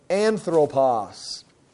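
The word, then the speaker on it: anthropos (ἄνθρωπος): The first one is the word, I'll pronounce it for you, you can kind of look at it right there, anthropos. Anthropos. Now, right away, that word ties into English, and we're anthropology, which is the study of man. And anthropos means man.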